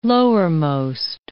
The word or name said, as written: lowermost